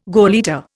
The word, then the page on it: gauleiter: Indian